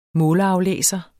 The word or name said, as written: måleraflæser